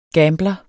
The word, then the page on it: gambler: [ ˈgæːmblʌ ]